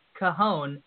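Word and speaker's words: cajón: Yes, and it is pronounced cajon.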